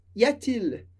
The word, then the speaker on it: y a-t-il: E nesse caso, e é uma maneira super formal de fazer essa pergunta, a gente coloca um T para ajudar na pronúncia. Y a-t-il? Y a-t-il? Fica mais fácil.